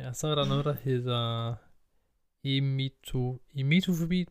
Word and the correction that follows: emetofobi: tror jeg det udtales. Emetofobi? Ja, Ja.